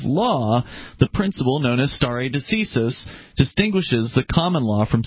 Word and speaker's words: stare decisis: He pronounced it, and I did look it up. It's stare decisis. – Stare decisis. – Latin, and in Latin it means let the decision stand.